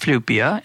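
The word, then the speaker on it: Flyvbjerg: for listeners, I'm going to tell you that his last name is spelled F-L-Y-V-B-J-E-R-G. I think I got that right. But it's pronounced Flupia,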